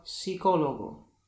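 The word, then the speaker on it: psicólogo: P podobnie jak w języku polskim, na przykład el pelo, el puerto, poza wyjątkiem, w którym po spółgłosce P występuje S, wtedy P jest nieme, na przykład psicologo.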